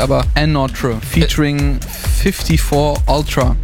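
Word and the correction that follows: ANOTR: Another heißt er.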